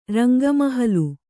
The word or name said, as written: ರಂಗಮಹಲು